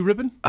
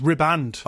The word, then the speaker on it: riband: Blue Ribbon? – Ah, I think I've heard this. Apparently that's wrong, isn't it? Is it ribbons? – It's riband. – Oh, riband.